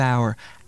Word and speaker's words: hour: Not that kind of hour. Hour with an H. – Oh, it's a hour hand. – The H is silent.